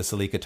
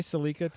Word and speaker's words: Celica: Like if you go back to the origins of that car, in Japan they say Celica, but it's almost like a hybrid between Celica and Celica the way they say it.